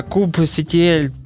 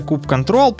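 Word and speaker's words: kubectl: Вот я, допустим, сейчас послушаю уже DevSecOps, короче, то все, в докер умею, э, куб CTL тоже знаю, даже знаю, что это не CTL, а куб control, правильно говорить.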